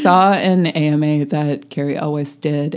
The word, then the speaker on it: Elwes: So also, I've been mispronouncing his name my whole life. – Gary Elwes. – Elwes, dear. – It is? – It's Elwes. – Why have we been saying Yule? – I know.